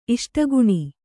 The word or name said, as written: ಇಷ್ಟಗುಣಿ